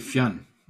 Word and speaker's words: Fionn: Most people break it into two syllables, which doesn't sound quite right, but you're saying it well. – All right, sweet. Well, like Fionn. Hey, Fionn. – Fionn or Fionn or something like that. – Oh, my goodness. One syllable.